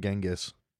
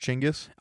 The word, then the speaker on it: Chinggis: You should name it Genghis. – I don't think you're talking into the mic. – I am. – Okay. Genghis? – Genghis? It's actually pronounced Chinggis.